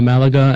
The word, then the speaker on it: Malaga: Yeah, no, and I guess that adds to year-round flights now that you can get from Norwich to Malaga and Alicante, which I'm sure I mispronounced completely.